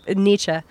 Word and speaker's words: Nietzsche: It doesn't yeah. rhyme with peachy. It rhymes. It's just the way that the Germans, Nietzsche.